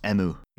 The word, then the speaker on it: Emu: It's spelled Emu. – Yeah, but it's pronounced Emu.